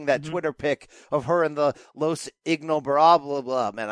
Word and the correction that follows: Ingobernables: Ingobernables, which is obviously a shout out to Andrade, her real life boyfriend, apparently right now, who started that faction in Mexico.